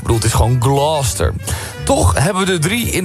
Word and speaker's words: Gloucester: De Engelse plaatsnaam Gloucester spreek je uit als... – Gloucester. – Gloucester. Ja. Als één vloeiend woord. Gloucester.